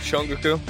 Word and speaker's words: Shongaqu: How do I pronounce this? Shongaqu? – Yeah, that's close enough. Shongaqu.